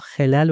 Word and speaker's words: خلل: در مقابلش ما خلل رو داریم، آنچه که میان دندان میماند. این رو با فتحه باید تلفظ کنیم.